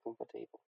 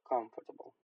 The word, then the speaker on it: comfortable: Слова vegetables и comfortable никак не связаны со словом table, поэтому не надо говорить vegetable и comfortable. Да? Vegetables и comfortable.